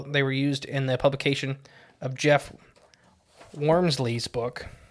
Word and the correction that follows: Wamsley: I think that's how you pronounce his name. Wormsley? – Yeah. So it's close enough. – Wamsley.